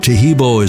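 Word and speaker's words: Taheebo: Tahibo is spelled T-A-H-E-E-B, like boy, O, then the word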